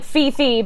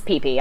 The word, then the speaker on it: Phi Phi: It's spelled like fee-fee, but it's pee-pee. All their H's are silent.